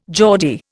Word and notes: Geordie: Indian

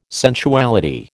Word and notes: sensuality: American English

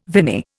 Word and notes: viny: Indian

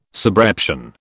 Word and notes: subreption: British English

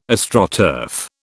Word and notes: Astroturf: British English

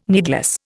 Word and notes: needless: Indian

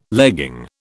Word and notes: legging: British English

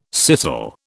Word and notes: scissel: British English

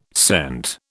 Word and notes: scent: British English

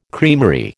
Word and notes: creamery: American English

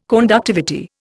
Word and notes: conductivity: Indian

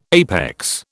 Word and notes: APEX: British English

